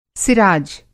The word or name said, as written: सिराज